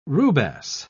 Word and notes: rubasse: British English

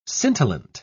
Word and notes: scintillant: British English

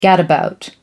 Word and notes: gadabout: American English

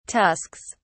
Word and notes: tusks: British English

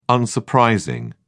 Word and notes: unsurprising: British English

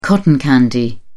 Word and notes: cotton candy: British English

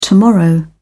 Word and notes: tomorrow: British English

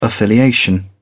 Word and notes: affiliation: English; British English

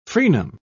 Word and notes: frenum: British English

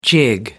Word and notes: jig: American English

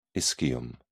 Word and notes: ischium: British English

